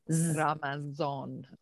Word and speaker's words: Ramazan: How do you say it in Persian? – Ramazan. – Okay. – Yeah, it's the same, right? For South Asians, it's called Ramazan.